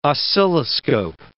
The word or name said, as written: oscilloscope